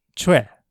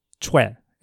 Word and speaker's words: Choe: In English, you'll see it romanized as C-H-O-E most of the time. And so many people will actually call him Cho. But the correct pronunciation is Choe. Sidebar, if you ever see the Korean name C-H-O-I or Choi, it's actually the same name pronounced Choe.